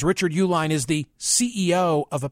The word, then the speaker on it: Uihlein: Nearly a third of Mastriano's fundraising, nearly a third, came from one wealthy company, Richard and Elizabeth Uihlein, although it's spelled Uihlein, billionaire conservative donors who live in suburban Chicago.